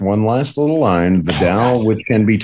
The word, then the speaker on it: Tao: Oh, sir, you pronounce Tao as Tao in Chinese.